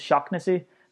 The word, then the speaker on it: Shaughnessy: And similarly, with people called Shaughnessy, if you have any guy called Shaughnessy, his actual name here in Ireland is Shocknessy.